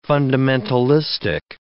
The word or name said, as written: fundamentalistic